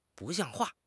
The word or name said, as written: 不像话